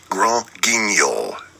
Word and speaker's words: Grand Guignol: It's pronounced Grand Guignol, actually. Bye.